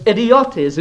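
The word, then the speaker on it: idiotes (ἰδιῶται): It's a Greek word pronounced idiotes, and you see it's got great overtones.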